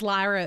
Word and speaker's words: Lyra: I'm going to say it again wrong. Lyra? – Yes. – Lyra. – You said it right. – Did yeah. It. – I thought it was Lyra at first, too, because it's spelled L-Y-R-A.